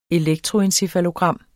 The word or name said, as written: elektroencefalogram